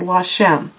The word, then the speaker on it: Lachem: And what I heard was Lashem, L-A-C-H-E-M. And I thought it's pronounced Lashem.